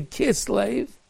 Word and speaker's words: Kislev: Kislev, as over the Rabbi Zogin, even though it's spelled with a vav, but they say Kislev.